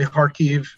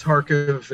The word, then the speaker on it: Kharkiv: Yeah, they pronounce it kind of incorrectly on all the news. They say Kharkiv, but it's Kharkiv. It's Kharkiv.